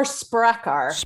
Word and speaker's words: Sprakkar: Sprakkar? – It's more Sprakkar, with an emphasis on the first syllable, but close enough.